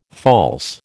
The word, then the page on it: false: American English